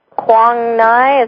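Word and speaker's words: Quang Ngai: Right, a Zen Buddhist monastery in Quang Nai. Is that how you say that? – God bless your heart. You're the only woman that's never been there that can say it right.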